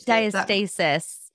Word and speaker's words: diastasis: It's it's, diastasis.